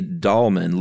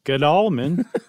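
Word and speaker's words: Gdalman: And it was a pharmacist in Chicago named, I'm going to say Dahlman, Louis Dahlman, with a silent G on the front.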